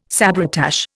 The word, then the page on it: sabretache: Indian